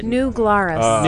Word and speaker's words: New Glarus: New Glarus.